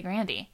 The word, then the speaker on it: Grande: Also, I almost said her name wrong, but I learned recently from an interview that That's actually the right way. You're supposed to say Grandy. That's Grandy? How she pronounces her own last name.